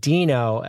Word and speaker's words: Deno: Deno, as in Fred Flintstone, is spelled D-I-N-O, but it's pronounced D-E-E-N-O.